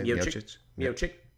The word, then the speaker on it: Miocic: How do you say Miocic? – Miocic.